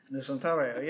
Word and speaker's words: Nusantara: Nusantara, yeah.